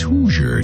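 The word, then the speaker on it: Hozier: His name is Hoosier, even though it's spelled Hoosier. It's Hoosier.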